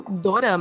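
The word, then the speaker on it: dorama (ドラマ): É, por causa do R. Drama, né? porque é. drama, no japonês, eles falam dorama drama. Uhum. Mas na Coreia, eles falam drama.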